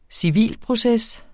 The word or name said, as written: civilproces